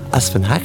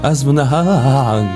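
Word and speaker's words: Asfenakh (اسفناج): Laissez-moi peut-être préciser la prononciation. Asfenakh. – Ah, je pense que ça se prononce plutôt Asfenakh.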